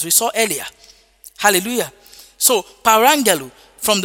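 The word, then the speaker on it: Parangelo (παραγγέλλω): But the word command there is the word parangelo. Hallelujah. Parangelo. Parangelo is spelled P-A-R-A-G-G-E-L-O. P-A-R-A-G-G-E-L-O.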